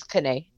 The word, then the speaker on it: Canay: But Ashley's Instagram is Ashley, just like it sounds, A-S-H-L-E-Y, and then Kanay, C-A-N-A-Y. Am I pronouncing your last name correctly, Ashley? – Yeah, it's actually my middle name. My last name's Morrow, but my middle name's Kinney.